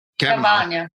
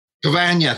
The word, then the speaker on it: Cavagna: You have Armando Cavagna. – Cavagna, thank you. I got to have my Brazil accent on there.